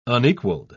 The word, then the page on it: unequaled: British English